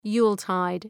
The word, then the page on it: Yuletide: 'ju:l,taıd